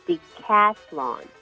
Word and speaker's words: decathlon: 10项全能运动叫做 Decathlon, D-E-C-A-T-H-L-O-N, Decathlon. – 十项全能的英文叫做 Decathlon, 就是它稍微比较困难发音一点，对不对？在发这个 TH 的时候，也要特别注意，舌头其实要稍微吐出来一点点，你才会发对这个音哦。